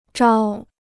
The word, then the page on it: 招: zhāo